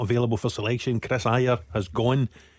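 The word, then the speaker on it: Ajer: Hold on, just before we go any further, it's taken until he's left for you to learn how to pronounce his name properly. Is that what you're telling me? – Adger's away to Brentford. – For the last 4 years he's been Adger. Now that he's gone, you're giving him his correct pronunciation.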